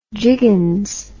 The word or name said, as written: jiggins